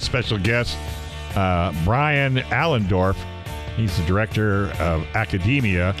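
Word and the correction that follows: Academi: Academy. – Well, who wrote Academy? – It's spelled funky, but it's Academy.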